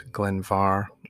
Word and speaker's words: Glen Mhor: Now, Glen Mhor is spelt Glen, obviously, but M-H-O-R, or you can say it Glen Var, or even just some people call it Glen Mhor.